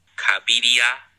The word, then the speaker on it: Cabiria: Here, wait, speaking of, I've just received a pronunciation guide. – Oh. – Cabiria and Machiste.